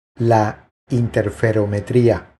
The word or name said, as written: La interferometria